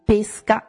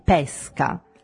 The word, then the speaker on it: pesca: Un esempio, per esempio, parliamo di pesca e pesca. – Ok, quindi pesca è aperto. – Pesca. – Pesca è aperto. In questo caso l'accento fonico verrà messo sulla E, sulla prima E di pesca, rivolto verso sinistra. – Quindi pesca è aperto e pesca è chiuso. – E pesca è chiuso.